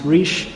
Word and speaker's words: Reich: Rob Riche, and we should all make a mental note, pronounced Riche, is one of three people with that name.